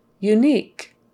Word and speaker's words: unique: So the word unique, unique, has kind of a K sound at the end.